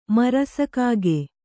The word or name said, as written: ಮರಸಕಾಗೆ